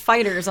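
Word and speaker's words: FighterZ: It's pronounced Fighters, unfortunately. – Okay, just Fighters.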